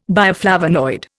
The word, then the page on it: bioflavonoid: Indian